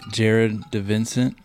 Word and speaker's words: Jared DiVincent: Today I am in Brunswick, Georgia at On the Fly Outfitters, joined by Jared DiVincent.